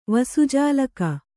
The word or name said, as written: ವಸುಜಾಲಕ